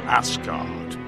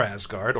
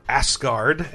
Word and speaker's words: Asgard: So, at the beginning of Viking Battle for Asgard, or Asgard, as he says, I don't think you should ever pronounce it like that.